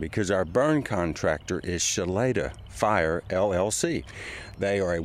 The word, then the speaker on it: Shloeta: Spelled S-H-L-O-E-T-A and pronounced Shaleda.